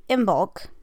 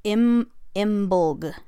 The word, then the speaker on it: Imbolc: So then the next one we have would be Imbolc. It can also be pronounced Imbolg, sorry, like I-M and then leave the B out and Olg.